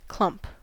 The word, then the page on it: clump: American English